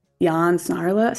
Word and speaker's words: Jan Snarlos: I have Andy Dicker on the podcast, aka Jan Snarlos. How do you say your Instagram name? – No, that was accurate. Yeah, it's Jan Snarlos. It's like Juan Carlos, but just Jan Snarlos.